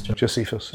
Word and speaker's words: Josephus: Josephus, you're saying, uh, uh maybe pronouncing that wrong, Josephus. – Josephus. Yeah.